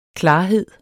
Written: klarhed

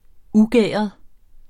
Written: ugæret

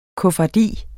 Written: koffardi

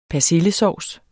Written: persillesovs

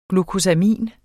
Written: glukosamin

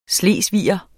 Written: slesviger

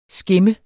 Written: skimme

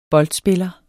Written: boldspiller

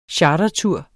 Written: chartertur

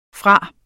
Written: fra

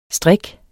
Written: strik

